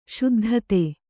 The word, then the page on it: ಶುದ್ಧತೆ: Kannada; śuddhate